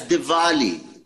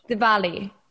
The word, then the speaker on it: Diwali: No, it's Diwali. Say verbia. – Because it's pronounced... – Diwali. – Vermont. – It's Diwali. – Spelled with a W. – V for Vermont. – Diwali.